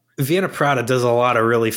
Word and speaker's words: Wiener Prater: How do you say that? – Wiener Prater.